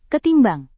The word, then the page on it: ketimbang: indonesian